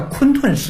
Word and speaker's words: 混沌: 就是天地混沌未开嘛，盘古开天地，盘古的姓氏叫盘古氏，又叫做混沌氏，但是那个字的古读音呢叫昆吞氏，就是讲的一个天地的一个，就是你这个在线条上。